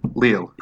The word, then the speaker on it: Lille: Lille? I don't understand Lille. – French. – Lille.